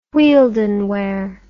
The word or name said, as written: whieldon ware